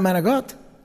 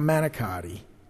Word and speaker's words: manicotti: They got manigot. And that's the right way to say it, by the way. Not manicotti. Don't say manicotti around me. Manigot.